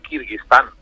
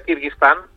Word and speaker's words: Kirguistán: Tú lo has pronunciado muy bien, Carlos, pero te aseguro que toda la gente ahora que le he dicho dónde me iba le ha costado millones pronunciar Kirguistán, porque claro, hay tantos tan, Kazajistán, Uzbekistán, Tayikistán, que se perdían un poco incluso con la pronunciación.